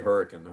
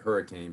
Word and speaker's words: Hurricane: And, you know, it's kind of like we say Hurricane. Hopefully people listen. Everybody else will pronounce it Hurricane.